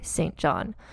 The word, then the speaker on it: St. John: You see how I pronounce that? That's what British people say, St. John.